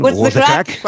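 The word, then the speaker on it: What's the craic: Creo que lo he dicho bien. – What's the crack? – What's the crack? Eso.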